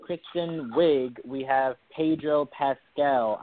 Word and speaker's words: Pascal: Pascal is right.